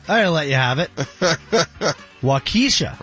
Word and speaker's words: Waukesha: Yeah, it's Waukesha.